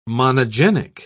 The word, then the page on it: monogenic: British English